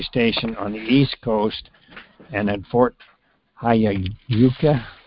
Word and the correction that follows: Huachuca: Robert will pronounce it better for me, HF station will conduct a high-powered broadcast on the 60-meter channel. – It's Huachuca. – Huachuca, yeah. Thank you, Terry.